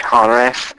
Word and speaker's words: charis: Grace, when I looked up the word grace, it says hares, hares, is C-H-A-R-I-S, but it's pronounced like an H, hares.